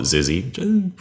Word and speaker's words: XYZZY: There's a magic word in Zork that is XYZZY, which is pretty much unpronounceable, unless you want to call it Zizzy. Pretty close.